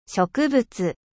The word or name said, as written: 植物